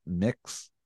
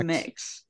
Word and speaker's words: Mx: And how do you say that? It's mix I think MX, it's mix. Yeah, I think it's, it's mix.